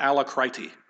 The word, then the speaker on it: alacrity: That's how you pronounce that word, not alacrity. Alacrity. Alacrity is a noun.